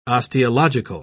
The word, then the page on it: osteological: British English